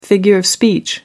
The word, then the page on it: figure of speech: American English